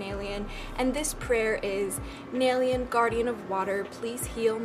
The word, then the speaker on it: Naelyan: It is spelled N-A-E-L-Y-A-N, although it's pronounced N-A-I-L-Y-O-N, so like Naelyon, but it's spelled like Yan, Naelyan, but you don't say it that way, you say it Naelyon.